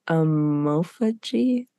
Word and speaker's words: omophagy: Yeah, sparagmos and Omophagy. – It's specific rites that they would do, which is basically like the sparagmos is like the disassembling, and the omophagy is the eating of the flesh, essentially.